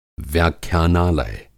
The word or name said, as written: व्याख्यानालय